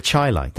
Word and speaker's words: Chylites: now, how did we agree that we're going to pronounce it? – The Chylites. – The Chylites. – Like highlights.